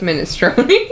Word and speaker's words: minestrone: When you I get why you wouldn't think milestone, because it's pronounced minestrone.